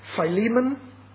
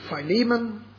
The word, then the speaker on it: Philemon: Philemon, as the word should be pronounced. Apphia is the correct pronunciation of the second word, Apphia, and Archippus. They are the three people addressed. Philemon, Apphia, and Archippus.